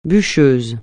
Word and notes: bûcheur: francais

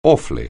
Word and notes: awfully: anglais

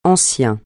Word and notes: ancien: francais

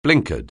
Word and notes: blinkered: anglais

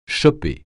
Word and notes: choper: français

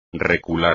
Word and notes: recular: espagnol

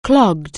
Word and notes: clog: anglais

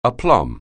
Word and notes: aplomb: anglais

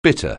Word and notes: bitter: anglais

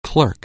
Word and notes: clerical: anglais

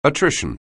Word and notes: attrition: anglais

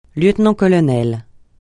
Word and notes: lieutenant-colonel: francais

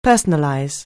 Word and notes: personalize: anglais

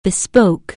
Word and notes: besotted: anglais

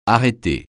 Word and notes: arrêter: francais

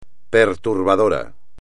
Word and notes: perturbador: espagnol

